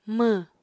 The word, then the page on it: mww: Apatani